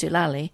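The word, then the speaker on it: Deolali: We waited a long 2 months in Dulali, pronounced Dulali, and meaning people sometimes went a bit bonkers, before sailing back on the ocean liner Empress of Australia.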